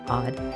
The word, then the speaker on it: Awed: Hi, and I want to welcome you to the Odd Life Podcast. That's spelled A-W-E-D, which stands for Awake, Well, and Empowered.